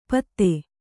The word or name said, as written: ಪತ್ತೆ